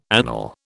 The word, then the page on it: anele: British English